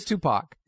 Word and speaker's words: Tupac: It is Tupac.